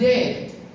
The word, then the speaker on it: debt: Dalam bahasa Inggris sebenarnya dikatakan debt. Debt itu adalah utang. Debt, D-E-B-T. Bukan debit. Itu gak ada debit. Itu bahasa Inggris orang Indonesia. Itu bacanya debt, karena D-nya silent.